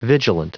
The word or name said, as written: vigilant